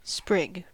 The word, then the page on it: sprig: American English